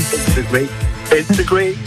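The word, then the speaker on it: Fade to Grey: Fat the Greek. – Non, vous l'avez bien prononcé. C'est très bien.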